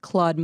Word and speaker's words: Claude: Extra points for Claude. Pronunciation. – Claude. Thank you. – It was really the. Wrong, that's okay. It was the breathiness.